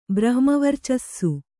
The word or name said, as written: ಬ್ರಹ್ಮವರ್ಚಸ್ಸು